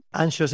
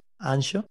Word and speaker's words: Anxo: Ancho se escribe A-N-X-O, ¿vale? Ancho.